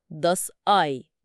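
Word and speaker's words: Ei: كلمة بيضة داس آي.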